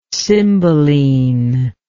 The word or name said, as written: cymbeline